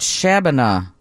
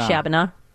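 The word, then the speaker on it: Shabbona: Is it Shabana? Are you there? How do you say it? Shabana. – You say Shabana, right. – It sounds like Shabana.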